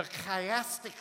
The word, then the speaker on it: chiastic: It's called the chiastic structure. You got to say it like that with me, okay? Say that. Chiastic structure.